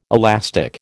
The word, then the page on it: elastic: American English